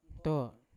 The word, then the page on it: nto: Yawa